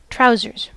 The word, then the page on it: trousers: /ˈtɹaʊ.zɚz/